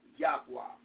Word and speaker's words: Yahweh: it is a mistranslation for YHWH, pronounced Yahweh.